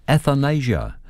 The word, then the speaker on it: athanasia: And before we launch into an episode of WOMAD Aotearoa 2024, an encore performance, this morning's word is athanasia. I'll spell it A-T-H-A-N-A-S-I-A, athanasia.